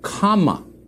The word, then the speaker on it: Kamala: It is pronounced comma, not semicolon, comma, like the punctuation mark, a la Harris.